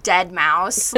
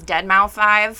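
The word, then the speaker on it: deadmau5: The hellhound in that was just, like, steroid dead mouse. Like, I just literally, wow, I can't believe I said that correctly. Sorry, I've been saying dead mouse as Dead Mouse 5 for, like, a decade, so I had to really think about that.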